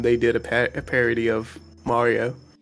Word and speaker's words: Mario: which is the proper pronunciation? I actually have no idea. I've been saying Mario my whole life. – Oh, no, you're right. It is Mario.